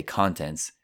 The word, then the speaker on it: content: And the spelling of contents is C-O-N-T-E-N-T-S. Notice the pronunciation. Content. There's also an adjective, content, contento. Content, content. Content is continuity with the stress on the first syllable, content. And last, guys, another verb here, to store. Store like un negozio, S-T-O-R-E.